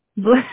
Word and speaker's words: Bleu: Her middle name is B-L-E-U. – Blue. – But it's spelled B-L-E-U.